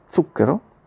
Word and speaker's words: zucchero: Lo stesso accade quindi anche a molti italiani con la parola appunto zucchero, che si pronuncia con la zeta sorda, come dicevo.